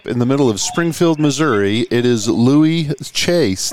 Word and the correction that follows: Chaix: Did I say that right, Louis? – Yeah, it's Louis Shakes. – Chakes. So the X is a hard X. Louis, yep. Chakes.